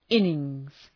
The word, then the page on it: innings: {‘ınıŋz}